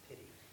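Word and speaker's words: piti: It's pity.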